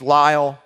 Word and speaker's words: Lisle: it's Lisle, S is silent.